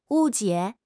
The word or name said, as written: 误解